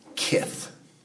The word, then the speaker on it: kith: The word is pronounced kith, much like the modern English word that descended from it.